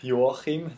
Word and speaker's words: Joachim: My name's Joachim. That's how you say it in my language.